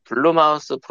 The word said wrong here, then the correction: 블룸하우스: 블룸하우스. – 예. – 블룸하우스. 블루 예. 마우스가 뭐야? – 블루 마우스라고 얘기했어요. – 아, 그 발음이 뭉개져서 블루 마우스로 들렸어 나도.